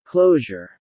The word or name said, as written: closure